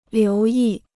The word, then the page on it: 留意: liú yì